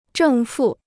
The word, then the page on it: 正负: zhèng fù